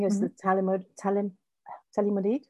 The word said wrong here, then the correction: thalidomide: or whatever. I can't pronounce meds names. – Is it the thalidomide? Is that what you're thinking? – Something like that, yeah.